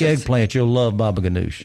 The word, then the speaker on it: baba ganoush: That's I saw that several people have baba ganoush. – Baba ganoush. – Am I saying that correct? – That's, right. – That's how I say it. – That's how you say it.